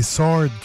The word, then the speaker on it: Sword: C'est Sword qu'il faut dire. – Sword. – Faut pas prononcer le W.